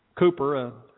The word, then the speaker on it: Cowper: He authored other songs as well, and he collaborated with another William named William Cooper, that I called Cowper until the other day when my deacon graciously corrected my pronunciation of his name. Thank you, Jared. Spelled Cowper. So if you don't know how to spell Cooper, spell it C-O-W-P-E-R.